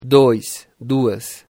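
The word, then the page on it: 2: Brazilian Portuguese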